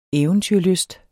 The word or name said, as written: eventyrlysten